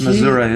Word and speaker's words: Missouri: But so the owner of the lodge is an ex-Marine named Carl from Missouri. And he... – Missouri is the correct pronunciation.